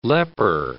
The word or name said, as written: leper